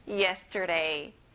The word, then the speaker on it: yesterday: Yesterday. Okay, now everyone, let's practice some pronunciation. 오늘, today, today. Very good. 내일, tomorrow, tomorrow. 뭐에 힘을 줘보세요. 어제, yesterday. 여기에 예에 힘을 줘볼까요? Yesterday. Excellent.